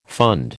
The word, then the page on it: fund: American English